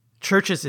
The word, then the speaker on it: Chvrches: Now, if you look up Churches, you're not going to find them because Churches is spelled very weirdly. It's not spelled C-H-U-R-C-H-E-S, the way you would think to spell Churches. It's spelled C-H-V-R-C-H-E-S, but it's pronounced Churches.